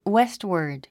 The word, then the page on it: westward: wéstwə r d; ウェストワード